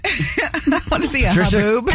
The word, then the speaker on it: haboob: I'm still stuck on a haboob. – Yep, that's how you say it. – A haboob? – Yeah, yeah.